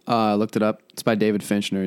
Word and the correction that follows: Fincher: He's the director. Fincher. David Fincher. I'm bad at pronouncing that.